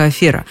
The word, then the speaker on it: афера: Правильное произношение, то есть по словарю «афера», у многих вызывает прям такие удивления. Гораздо чаще говорят иначе «афера». Объяснить, откуда это произношение взялось, мне сложно, потому что логики в нем, на первый взгляд, нет никакой. Итак, если правильно, то афера. И только афера.